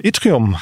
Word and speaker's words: Yttrium: Sehr schön, ich bin verbunden mit Julian Mattes, Partner von Itrium.